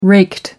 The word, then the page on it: raked: American English